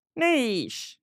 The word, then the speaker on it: niche: At this point in time in the U.S., Niche with a C-H is still the more common pronunciation, but Niche is gaining ground. Our evidence suggests that in British English, niche is now the more common pronunciation.